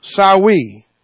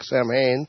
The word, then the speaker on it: Samhain: This one is Sawi, pronounced Celtic. Not the way it looks like, Samhain. No, it's Sawi.